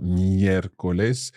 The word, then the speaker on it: miércoles: Another is rújula, miércoles. Yes. So again, our accent sits on that first E in the word, miércoles. – Mm-hmm. – And we are stressing the third last syllable or the antepenultimate syllable.